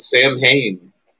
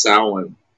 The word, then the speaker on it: Samhain: Everybody calls it Samhain. – Oh, shit. – But it's actually pronounced Samhain.